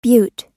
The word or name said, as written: bute